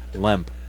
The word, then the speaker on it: LEMP: LEMP. – Oh, it's LEMP? – Okay. Yeah, with an N LEMP. For Nginx. – No, with an E. Wait. They use an E. – Oh, LEMP?